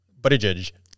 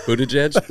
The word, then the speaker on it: Buttigieg: Buttigieg. – Buttigieg? – We'll call him Booty.